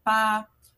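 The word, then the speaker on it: pá (八): Que se pronuncia pá, porque o som de pá, 8, é parecido com o som da palavra prosperar, que é fá, tsai, fá, esse fá e pá.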